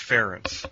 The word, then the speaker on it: Ferentz: Is it Ferentz or is it Ferentz? – It's Ferentz. – Ferentz. Okay. Rhymes with parents.